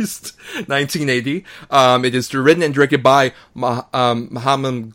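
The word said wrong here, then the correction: Menahem: Golem. I apologize. – Menheim. – Thank you.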